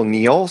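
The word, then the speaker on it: Neal: I am the real Neil. So don't make this Starbucks barista rookie mistake. It's N-E-A-L.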